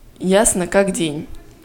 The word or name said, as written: ясно как день